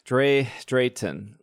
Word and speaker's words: Drayton: Let's talk about Drayton. Drayton. That's how you say it.